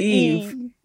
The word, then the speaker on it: Yves: Yves, right? There's an Eve. – It's without the S, but my S is pronounced.